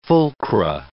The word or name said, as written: fulcra